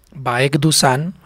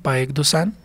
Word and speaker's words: Baek Dusan: Para los que quieran, de pronto, ese es el número de WhatsApp, o nos pueden encontrar en Facebook como Baek Dusan, así tal cual como lo pronuncio, Baek Dusan.